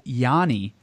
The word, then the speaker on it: Giannis: And he was telling me the other day that where the region in Greece where Giannis is from, they actually drop the S and don't say the S. – Ooh. – And it's actually pronounced Yanni.